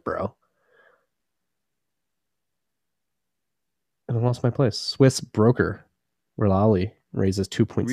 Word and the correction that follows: Relai: Relai? How do you pronounce it? – Relai. That's what I went with.